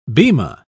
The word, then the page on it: bema: British English